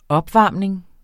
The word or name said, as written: opvarmning